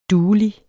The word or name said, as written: duelig